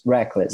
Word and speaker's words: Reckless: Taylor Momsey, do The Pretty Reckless, Reckless ou Reckless? Reckless. – Reckless. Tá.